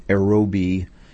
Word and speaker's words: Errobi: The group is called Aerobi, E-R-R-O-B-I.